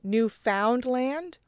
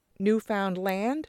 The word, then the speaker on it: Newfoundland: He popularized the first pronunciation where found becomes Finn, like Newfoundland.